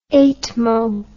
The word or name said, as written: eightmo